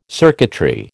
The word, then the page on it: circuitry: American English